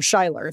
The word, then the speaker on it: Schuyler: George Schuyler, or Schuyler, if you pronounce it that way, is another example from the Pittsburgh Courier.